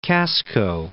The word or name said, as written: Casco Bay